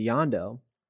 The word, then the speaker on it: Yondu: And what's interesting is he latches on to Yondu, right? That's how you say his name? Okay, thank you.